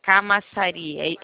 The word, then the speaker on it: Camaçari: O município é Camaçari. É assim que se pronuncia, é, né? – Sim, fica maçaria.